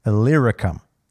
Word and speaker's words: Illyricum: From Jerusalem, he says, from there all the way to, I'm going to cover, he says, all the way around to Illyricum.